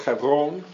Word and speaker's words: Hebron: Hebron, you'll hear some people pronounce it. Hebron would be the Hebrew way of pronouncing it.